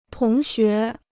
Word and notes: 同学: tóng xué

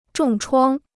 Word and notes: 重创: zhòng chuāng

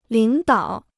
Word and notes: 领导: lǐng dǎo

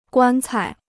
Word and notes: 棺材: guān cai

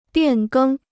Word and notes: 变更: biàn gēng

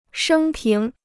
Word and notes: 生平: shēng píng

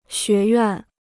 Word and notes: 学院: xué yuàn

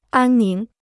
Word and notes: 安宁: ān níng

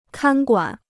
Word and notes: 看管: kān guǎn

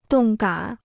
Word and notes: 动感: dòng gǎn

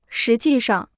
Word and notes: 实际上: shí jì shàng

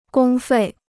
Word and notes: 公费: gōng fèi

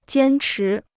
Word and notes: 坚持: jiān chí

